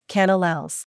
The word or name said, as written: Canellales